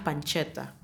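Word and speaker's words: pancetta: Me da mucha risa panceta, en italiano es pancheta. O sea, se pronuncia pancheta, pero es lo mismo.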